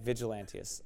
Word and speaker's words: Vigilantius: You said Vigilantius? Why'd you get it wrong? I thought you were pronouncing it right, and I was pronouncing it wrong, maybe. I was thinking you pronounced it eloquently like Apollos, and I pronounced it rude in speech like Paul. Right? That's what I thought. Okay, Vigilantius. I was right then, okay.